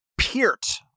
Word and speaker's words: Neil Peart: Neil Peart, I have discovered his name is pronounced, although I don't believe it. So I'm going to... – Peart. – Part of my reading is like, actually, it's Peart.